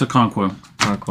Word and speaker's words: Okonkwo: Chigozium Okonkwo. – No, it's a Okonkwo.